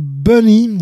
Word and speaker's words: B2NY: Juste à suivre maintenant, on va entendre Bunny. Alors ça ne s'écrit pas Bunny, ça s'écrit B2NY, mais c'est bien ça que ça veut dire à l'origine.